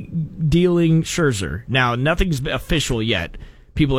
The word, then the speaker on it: Scherzer: Am I saying his name right? – Yep. – Scherzer? – Scherzer.